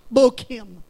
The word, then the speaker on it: Bochim: The Hebrew word is actually pronounced Bochim, and it means literally the place of weeping.